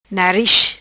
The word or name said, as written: Nariz